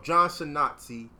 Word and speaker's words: Schnatter: But fuck Papa John's Pizza and that nigga, whatever his name is, I can't pronounce his name, John Schnatter? – Doesn't matter. – Damn, Schnatter. There we go. John Schnatter.